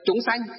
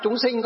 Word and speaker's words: chúng sanh: Chúng sanh. Thì trong miền Nam họ đọc chúng sinh có nghĩa là chúng sanh.